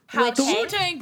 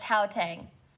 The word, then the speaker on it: Gauteng: I will only drink if you admit that it's pronounced Hautang.